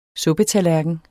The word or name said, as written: suppetallerken